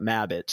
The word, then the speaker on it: Mabbit: Two B's, one T, like they're trying to spell rabbit, but Mabbit.